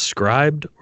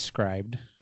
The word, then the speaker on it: Scribd: So I recently discovered, and is it pronounced scribed or the app SCRIBD? Do you know what I'm talking about? – Oh, yeah. I think it's scribed.